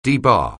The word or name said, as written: debar